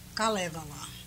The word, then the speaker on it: Kalevala: Once a year, the Finnish community here has an evening for the Kalevala, as it is pronounced in Finnish.